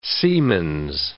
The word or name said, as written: siemens